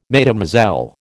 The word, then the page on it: Mesdemoiselles: American English